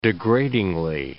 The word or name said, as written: degradingly